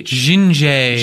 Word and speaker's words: Zsinjeh: Is that how you think you pronounce it? Z-S-I-N-J-E-H. – Zinje. – Zinje bought the pro version this week.